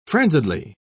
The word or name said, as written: frenziedly